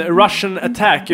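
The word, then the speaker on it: Rush'n Attack: Så Rush and Attack heter det ju. Men Russian Attack uttalas det, och det låter ju jävligt likt Russian, som är ryska liksom.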